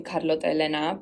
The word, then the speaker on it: Carlotta Elena: So it's a very Spanish-sounding name, Carlotta Elena, but it's spelt the Italian way because, I mean, my dad chose my name and it was kind of a family name.